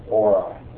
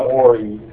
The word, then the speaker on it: Ori: And by the way, just you need to officially, you know, get out there and explain that it's Ori, not Ori and not Oreos. It's O-R-I, pronounced Ori.